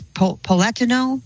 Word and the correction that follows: Platinow: Yeah, it's Platinow.